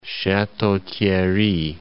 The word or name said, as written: Chateau-Thierry